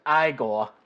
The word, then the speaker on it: Igor: Well, if it's Frankenstein, then I'm Igor. – No, it's pronounced Igor.